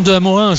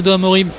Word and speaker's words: De Amorim: On dit bien De Amorin, je le dis, hein, depuis le début de la saison, tout le monde dit mais pourquoi tu dis De Amorin, que c'est De Amorim? Ça s'écrit De Amorim. En portugais, ça se dit De Amorim. Mais lui souhaite qu'on dise De Amorin, Mathias De Amorin. Donc je dis Mathias De Amorin comme il le souhaite lui-même.